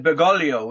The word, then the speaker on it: Bergoglio: When the Cardinal came out onto the balcony for the famous Habemus Papam, we have a Pope announcement, and he said, Bergoglio, or he said Bergoglio, which is the way the Italians pronounce it.